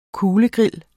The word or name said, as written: kuglegrill